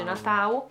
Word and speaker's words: Natal: legge Natau e,